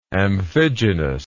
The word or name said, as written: amphigenous